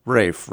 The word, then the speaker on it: Ralph: Now, it's spelled R-A-L-P-H, but the English pronounce it Rafe rather than Ralph. We'd say Ralph.